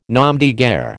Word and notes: nom de guerre: American English

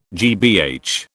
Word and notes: GBH: British English

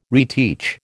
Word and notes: reteach: American English

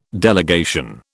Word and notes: delegation: British English

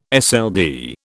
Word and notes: SLD: British English

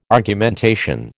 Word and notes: Argumentation: American English

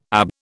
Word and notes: ab: British English

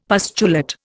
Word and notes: pustulate: Indian

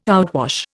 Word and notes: outwash: Indian

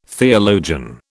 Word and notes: theologian: British English

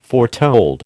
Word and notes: foretold: American English

